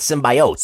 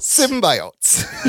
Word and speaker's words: symbiote: Well, when I eat the cereal, I call it symbiotes. – Symbiotes. – Yes. – It's symbiote. – Symbiote? I would have said symbiote.